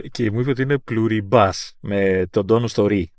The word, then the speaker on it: Pluribus: Αυτό θα είναι Pluribus, αφού είναι, αυτό θα είναι λατινικά, ε.